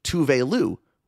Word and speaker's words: Tove Lo: When she made her appearance on RuPaul's drag show, she was introduced not as Tove Lo, but as Tove Lou, which had Twitter in a tizzy.